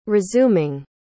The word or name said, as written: resuming